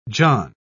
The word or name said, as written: john